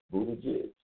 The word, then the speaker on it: Buttigieg: Heard of the guy Mayor Mayor Pete Buttigieg is how you pronounce the last name, whatever.